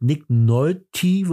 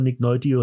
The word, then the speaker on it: Nick Nolte: Mit Nick Nolte wird ja, glaube ich, ausgesprochen. Nick Nolte, Nick Nolte, Nick Nolte.